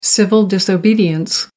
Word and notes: civil disobedience: American English

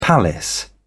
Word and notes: palace: British English

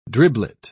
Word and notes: driblet: British English

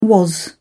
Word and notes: was: British English

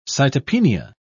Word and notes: cytopenia: British English

